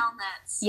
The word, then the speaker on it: Yelnats: So Stanley Yelantis, I don't know how to say his last name. – Yelnats. – Yelnats. Oh, that makes sense.